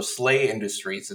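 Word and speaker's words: Sla Industries: So, Slay Industries. It's spelled SLA.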